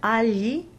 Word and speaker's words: agli: So although the word agli, A-G-L-I, would normally be written phonetically as bright A, lambda, lambda, lowercase I, not forgetting that generally the combination GLI is considered a double consonant.